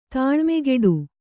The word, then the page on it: ತಾಳ್ಮೆಗೆಡು: Kannada